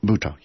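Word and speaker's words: Butoh: Okay, now I have friends who would be extremely interested in that because they like Japanese art and they like Buto. Is that how you pronounce it, Buto? – Buto, yes.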